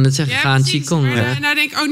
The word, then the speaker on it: Qigong: Eh, ik kan niet uitspreken. Kishong? – Qigong. – Qigong.